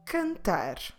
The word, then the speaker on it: cantar: So every time you see this letter followed by the vowels A, O, U, your pronunciation should be a hard Q. Let's see some examples. Cantar. Cantar. Cantar means to sing. Como se escreve cantar? C-A-N-T-A-R.